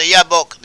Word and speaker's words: Jabbok: and they crossed over the Yabbok. The Yabbok is a river. Jabbok is how it's spelled in English. 32, Genesis 32:22. Jabbok or Yabbok in Hebrew.